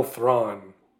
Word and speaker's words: Thrawn: And in the trailer, little teaser they showed, you see the back of Admiral Thrawn.